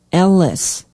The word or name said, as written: ellis